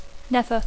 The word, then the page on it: never: English